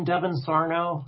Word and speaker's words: Devin: Yeah, so just my name, Devin Sarno.